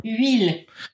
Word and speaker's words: huile: Huile. Huile. French word for oil.